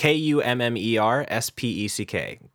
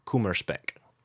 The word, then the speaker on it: Kummerspeck: Um, I'm going to butcher this, but Kummerschmeck? Kummerschmeck? How do you spell um, it? K U M M E R S P E C K. Kummerspeck.